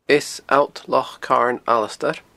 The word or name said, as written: Eas Allt Loch Carn Alasdair